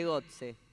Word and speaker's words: Götze: de Götze. ¿Se pronuncia así? ¿Fue con él? – Sí, va.